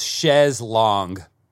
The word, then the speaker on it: chaise longue: He gets to the trunk and then he goes to the chaise lounge. How do you pronounce it? – So it's chaise longue.